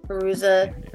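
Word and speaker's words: Fairuza: Faruka. – Oh. Faruza Balk? – Faruza Balk, yeah. I couldn't remember how to say her first name.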